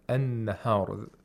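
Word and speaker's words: النهار: And also the lam is not pronounced, just like the previous word. We have al-layl and an-nahar.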